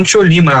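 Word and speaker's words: Chollima: Vamos nessa então falar sobre a Seleção Tcholima, falei certo? Seleção Tcholima? – Falou mesmo, Seleção Tcholima, a lendária, conhecida até hoje, mesmo que tanto tempo tenha se passado.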